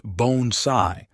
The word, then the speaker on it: bonsai: "Bonsai is pronounced bone-sai, and the smallest trees," she extended the tiny juniper, "are called shito bonsai.